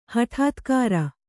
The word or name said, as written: ಹಟಾತ್ಕಾರ